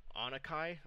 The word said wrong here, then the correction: Oninaki: It's Oninaki.